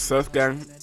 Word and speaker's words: Surf GVNG: Like the next track I'm gonna play, there's a guy called, okay, right, you see this Surf Given, yeah, is that how you say it? – I thought it was Surf Gang. – Oh, it's got GVNG. What does that mean? Surf Gang. – I'd probably say gang, you know.